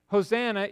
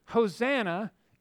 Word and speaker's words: Hosanna: Here, Hosanna in the Greek is a word that is pronounced the same as the Hebrew phrase, Hoshea Na.